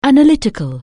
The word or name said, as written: analytic(al)